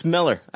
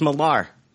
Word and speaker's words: Millar: I found out it's pronounced Miller. I've always thought it was Millar.